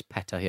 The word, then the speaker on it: PETA: But before we go to emails, which we'll do a bit later, did you see this thing from, is it pronounced PETA? People for the Ethical Treatment of Animals? – I think it's PETA, yeah. – PETA, okay.